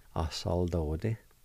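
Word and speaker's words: asaladodi: And it's pronounced like this. A-sa-la-do-ti. A-sa-la-do-ti. A-sa-la-do-ti. And once in a while you might hear somebody say it like this. The A with the L would be silent. And it will sound like this. a soldade.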